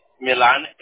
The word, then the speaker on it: Millan: V-I-C? – E-N-T-E. – Got it. And what is your last name? – Milan, M-I-L-L-A-N.